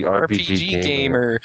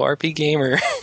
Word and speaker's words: RPGamer: Sorry, there's only one People feel very shocked when they find out that we pronounce it RP Gamer. So Just so you guys know, that's the official pronunciation.